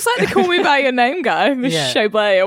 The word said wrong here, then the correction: Chalamet: or whatever his name is. – Timothée Chalamet. – Chalamet. Yeah. I did fucking School of Rock on his surname. Sorry about that.